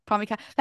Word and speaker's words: Pomykala: Pomykala.